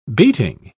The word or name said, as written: beating